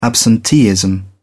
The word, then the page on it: absenteeism: British English